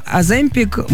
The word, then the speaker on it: Оземпик: Уколы, Оземпик, Муджара, я все правильно говорю? – Да, да, да.